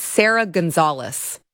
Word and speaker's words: Sarah Gonzalez: It's Sarah Gonzalez. That's how you say it.